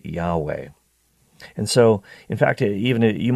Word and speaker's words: Yahweh: And so in the old days, people used to pronounce this name as Yehovah or Jehovah. And that's where we get the name Jehovah from in the Old Testament. But now, these days, in these times, people and scholars tend to say, no, it actually is probably pronounced Yahweh, Yahweh.